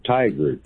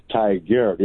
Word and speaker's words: Tigard: it almost sounds like tiger, but it's Tigard. It's like T-I-G-A-R-D.